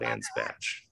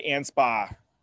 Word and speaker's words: Anspach: A-N-S-P-A-C-H. – Oh, ANSPA. – I saw ANSPA. I've heard other people say ANSPATCH. – No, they're dumb. It's not called ANSPATCH.